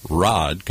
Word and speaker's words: Rad: And we welcome you to The Rad Life, the only show with Rod Gontos. I know it's spelled Rad, but we pronounce it Rod.